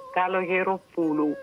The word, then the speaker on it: Kalogeropoulou: Kalogeropoulou.